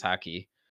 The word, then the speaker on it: Haki: Yeah, Haki. Yep. – Yeah, yep. – Haki. It seems like it comes out of nowhere. And then it's like, they've been doing Mantra, which is Haki.